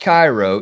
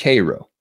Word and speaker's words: Cairo: And it's spelled C-A-I-R-O, but do not confuse it for that town in Egypt. It's not pronounced Cairo. It's pronounced Cairo. You want to let people know you ain't from around here? Then call it Cairo.